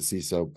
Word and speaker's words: CISO: We pronounce it CISO, other people pronounce that acronym in other ways.